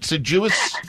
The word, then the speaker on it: deciduous: Number two, a tree that loses its leaves in the fall is called a deciduous tree. Is that how you say it? Deciduous? – Deciduous.